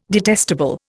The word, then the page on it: detestable: Indian